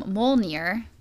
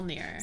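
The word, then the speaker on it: Mjölnir: Um, he carries around his hammer named um, Molnir. That's how you pronounce it. M O L. Mjolnir.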